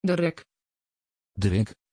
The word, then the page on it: dereck: Dutch